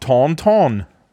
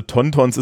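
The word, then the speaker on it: Tauntauns: Ähm, also Tontons ist natürlich deutsche Aussprache, ne? Tontons ist es.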